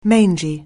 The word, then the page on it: mangy: anglais